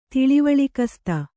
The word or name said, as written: ತಿಳಿವಳಿಕಸ್ತೆ